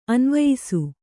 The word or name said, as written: ಅನ್ವಯಿಸು